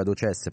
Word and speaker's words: ADOCES: il progetto della Federazione Italiana ad OCES. l'accento è sulla E dunque, ad OCES, perché prima ho detto ad OCES.